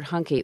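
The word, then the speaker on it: Huncke: Talk about the role in their social and literary equation played by Herbert Hunke, which rhymes with junkie, which was only one of his antisocial sidelines.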